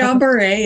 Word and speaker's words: Guillain-Barré: Is it Guillain-Barré or how do you say it? – It's Guillain-Barré. Yeah. Guillain-Barré. So it is. Okay. It's French.